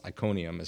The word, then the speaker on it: Iconium: This is about Paul and Silas being imprisoned, and I think it's Iconium is how you say it. – Yep.